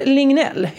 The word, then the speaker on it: Lignell: Emil Lingl... det uttalas däremot inte så. Emil Lignell heter han ju.